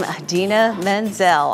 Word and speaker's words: Idina Menzel: And I see you went with the proper pronunciation and not the John Travolta pronunciation for her name, Idina Menzel, on tour.